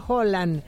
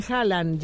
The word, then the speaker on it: Haaland: Ya que hablan de pronunciación, Haaland se pronuncia Holland, pero ya todos le dicen Haaland, ya fuera de Escandinavia.